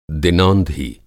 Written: दिनौंधी

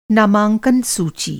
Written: नामांकन

